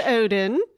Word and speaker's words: Odden: So, first of all, just FYI, my last name is pronounced Odin, kind of like the like the Norse god who ripped an eyeball out of his head in exchange for power.